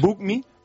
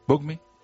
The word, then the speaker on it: Bucmi: Es la forma de decir en español, como se pronuncia BookMe, que en inglés quiere decir resérvame.